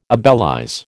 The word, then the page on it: obelize: American English